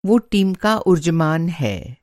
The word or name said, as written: ऊर्जमान